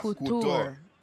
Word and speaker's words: Couture: It is Le Trap Couture. – Okay. – Couture. – Say it properly.